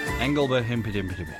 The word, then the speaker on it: Engelbert Humperdinck: an Italian pop song done here by Engelbert Humpeldumpel. Engelbert Humperdinck. Engelbert, oh, I always get his name wrong.